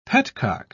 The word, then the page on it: petcock: British English